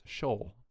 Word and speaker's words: Schaal: The trivia on her, her last name is pronounced Schaal.